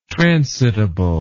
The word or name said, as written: transitable